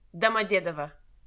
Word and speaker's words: Domodedovo: Domodedovo. – Ah, Domodedovo. – Oh my. I couldn't have been further out. – it's the emphasis.